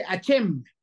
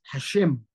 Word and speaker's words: Hashem: El que no lo sabe pronunciar y a veces duele en el oído cuando yo escucho personas dicen, es que Hachem, Hachem, Baruch Hachem, a mí me pega en el oído y es como unos golpe en la cara así, porque está mal pronunciado. Es Hashem, Baruch Hashem.